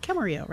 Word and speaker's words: Camarillo: Cameron Yee from Camarillo. Am I pronouncing that correctly? Camarillo, right? – Yes.